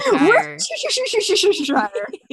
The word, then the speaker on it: Worcestershire: So he had the respiratory problems and went to a health resort town of Malvern, Worcestershire. – You just added like 5 syllables to that. – I'm giving up on the Worcestershire. – Worcestershire. – Like a horse.